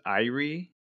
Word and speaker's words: IRE: So this organization, it's spelled IRE, and three letters, IRE. IRE is pronounced Irie.